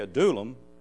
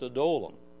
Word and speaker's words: Adullam: He said, you say Adullam because it rhymes with praise unto him, but that's not how you pronounce it in its original form. It's Adullam.